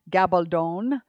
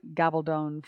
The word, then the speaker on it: Gabaldon: I'm a big fan of Diane, Diana Gabaldon. I just recently realized that was the, or, or, learned that that was the correct pronunciation, I think, of her last name. If you're a Diana Gabaldon fan and you know otherwise, please send me a message.